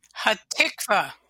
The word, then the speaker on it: Hatikva: Hatikva, the national anthem of Israel. Is it, I get it right? – Close. Hatikva. We stress the second syllable. – I put the accent on the wrong syllable.